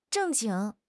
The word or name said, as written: 正经